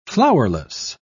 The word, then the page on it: flowerless: British English